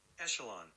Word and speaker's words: echelon: ECH, ek. – Echelon. – If it's the beginning of a word. Fuck, I don't want a bike. I want you to pronounce it for me. – I don't want a bike. – Just what the difference of calling echelon. – I think I'll eat my hat. – Echelon. – Echelon. Apologies, I was wrong.